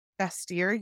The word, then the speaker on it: Bastyr: From UCLA and a doctorate in naturopathic medicine from Bastyr University.